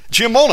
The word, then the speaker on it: Giammona: Giammona.